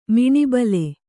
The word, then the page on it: ಮನೋಬಲ: manōbala